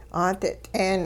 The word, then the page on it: 'aadit'een: hupa